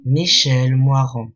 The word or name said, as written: Michel Moyrand